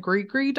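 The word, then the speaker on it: gris-gris: It's is it Grigri dolls? – I feel like that's more accurate than me saying gris dolls.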